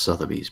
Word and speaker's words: Sotheby's: At Sotheby's. I mean, the thing is that the original photographs, the faded photographs, And it's pronounced Sotheby's, by the way.